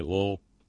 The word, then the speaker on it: Louisville: And that is the correct pronunciation, by the way, Lowell. It's almost not even a pronunciation at all, Lowell.